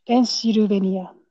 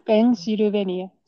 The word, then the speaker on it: Pennsylvania: They say the still, right? Stressing. – Mm. – Yeah, but you have to get it flattened. So, Pennsylvania. Pennsylvania. So, all sounds are equal to be pronounced. Then it sounds more Japanese.